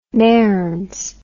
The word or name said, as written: mearns